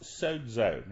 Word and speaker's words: sozo: It is a Greek word and is pronounced sozo. Now, it's spelled S-O-Z-O in terms of the English transliteration of that word. But it's pronounced sozo. There's a little D sound in there.